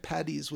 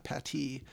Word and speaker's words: Paddy: So Padraic would be Paddy, not Patty.